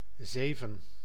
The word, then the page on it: sept: France; [sɛt]; /sɛpt/